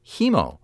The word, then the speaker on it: Hemmo: Actually, you know, I said Hemo. I believe he says Hemo. I remember I was surprised by that. It's spelled H-E-M-M-O, but he says Hemo, Philip Hemo.